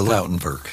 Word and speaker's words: Lautenwerk: The Lautenwerk. – The Lautenwerk? – Yes.